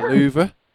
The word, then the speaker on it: Louvre: The Louvre. – The Louvre.